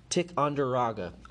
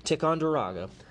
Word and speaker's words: Ticonderoga: The cannon seized at Ticonderoga. I got too cocky, I said it wrong. Ticonderoga would make their way to Boston to help free the city from the British.